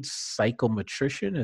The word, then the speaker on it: psychometrician: And could you, at least for now, it's lead psychometrician.